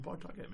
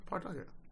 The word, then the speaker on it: Pawtucket: Yeah, or maybe we do it last regular game of this season in Pawtucket.